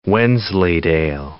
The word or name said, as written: Wensleydale